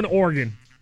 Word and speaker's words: Oregon: I'm just going O-R-G-A-N. I'm just going Oregon. Because it's almost like you put the little E in there or something like that, but you're not really pronouncing it. It's Oregon, but you're saying it so fast.